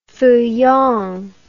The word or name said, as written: feuillant